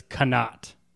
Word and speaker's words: qanat: How do you spell it? Q A N A T, but it's quinat.